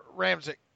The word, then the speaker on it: Ramczyk: You know how to pronounce his name? Ryan Ramczyk. Is it Ramczyk? – Ramczyk. – Ramczyk, yeah. So